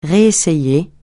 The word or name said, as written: réessayer